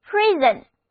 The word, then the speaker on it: prison: 我们在这个单词中间发现了表示抓、抓获的词根 ,pris, 后面加上了一个 on 的名词后缀，那么 prison 就是我们关押、抓来的罪犯的地方，就是监狱。字母 O 在这里不发音， I 字母发作短的 E，单词就读作 prison，监狱。那么监狱里人满为患。